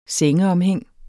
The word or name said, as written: sengeomhæng